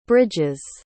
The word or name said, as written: bridges